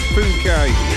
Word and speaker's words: Phuket: And let's not read it out the way it's spelt. We've not long started, have we? So, yeah, I think it's Phuket.